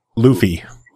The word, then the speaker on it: Luffy: Luffy. At least that's how they say it in the game.